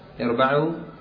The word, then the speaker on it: اربعوا: اربعوا نطقها مثل ما تنطق كلمة امنعوا، إذا ابتدأت تكسر الهمزة وتفتح الباء. اربعوا، اربع على نفسك، اربع على نفسك أي هون عليها. وإذا وصلت تقول: يا أيها الناس اربعوا، تسقط همزة الوصل والباء تبقى مفتوحة في كل الأحوال.